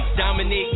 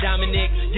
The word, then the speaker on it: Dominique: G-O-M-I-M-I-M-I-M-I-G-O-G-O-M-I-M-I-M-I-M-I-G-O-G-O-M-I-M-I-M-I-M-I-M-I-N-I-Q-U-E. Dominique, not Dominic.